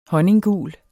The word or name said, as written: honninggul